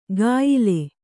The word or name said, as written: ಕಾಯಿಲೆ